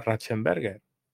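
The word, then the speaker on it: Ratzenberger: Ratchenberger.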